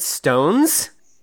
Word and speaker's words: SixTONES: So like six tones, but they pronounce it stones, which doesn't make any sense.